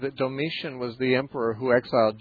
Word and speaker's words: Domitian: or is it Domitian, is that how you say his name? – Yeah, Domitian wasn't until later.